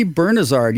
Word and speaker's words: Bernazard: Bernazard? Is that how you say it? – Tony Bernazard, yes.